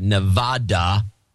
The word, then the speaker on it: Nevada: If Hot Pockets is going to be near Northeastern Nevada, pronounce it right, Nevada.